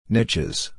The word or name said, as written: niches